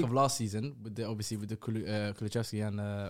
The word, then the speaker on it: Kulusevski: Kulusevski. There's no CH.